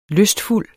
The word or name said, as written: lystfuld